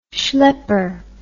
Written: shlepper

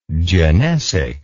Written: genesic

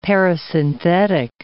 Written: parasynthetic

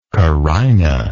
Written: carinal

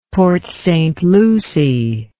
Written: Port St. Lucie

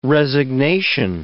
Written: resignation

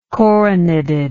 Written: coroneted

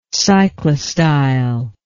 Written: cyclostylar

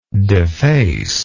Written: defacer

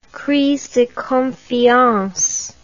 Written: crise de confiance

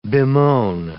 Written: bemoan

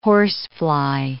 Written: horsefly

